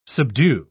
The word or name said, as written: subdue